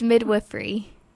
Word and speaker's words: midwifery: Am I pronouncing it right? Is it wifery or wifery? – It's midwifery.